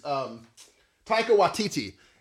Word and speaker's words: Taika Waititi: Is it Taika? How do you say his name? It's Taika Waititi.